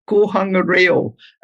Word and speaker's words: Kohanga Reo: It's a Maori language nest, but it's Kohangareo. And I'll spell it for you. – Okay. – K-O-H Kohanga, Kohanga, and Reo is capital R-E-O. That's Reo is language, and the Kohanga, Kohanga Reo, that's Maori language.